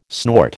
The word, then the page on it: snort: American English